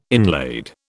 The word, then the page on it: inlaid: British English